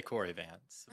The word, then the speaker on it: Kory Vance: I just say Corey Vance.